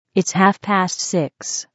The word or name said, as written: half past six